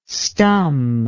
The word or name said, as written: stum